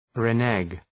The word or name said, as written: renege